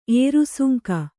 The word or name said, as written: ಏರುಸುಂಕ